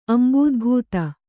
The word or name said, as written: ಅಂಬೂದ್ಭೂತ